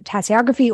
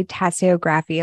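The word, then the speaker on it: tasseography: Let's first touch on tea leaf reading, tassiography, right? – Yeah, you can pronounce it tassiography or tassiography or even tassiomancy.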